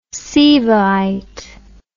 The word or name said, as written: Sivaite